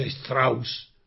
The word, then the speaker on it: Strauss: Y en alemán, que ese nombre Strauss es alemán. Y es verdad que él es francés. Y en francés se diría Strauss-Kahn. Pero nadie sabe lo que significa si no dice Strauss, porque el apellido es alemán.